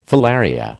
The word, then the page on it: filaria: American English